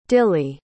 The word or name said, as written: dillie